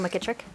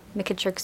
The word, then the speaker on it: McKitrick: Nicole McKittrick. – Would you spell that, please, for me? – N-I-C-O-L-E. McKittrick, spelled M-C-K-I-T-R-I-C-K. – M-C what? – M-C-K-I-T-R-I-C-K.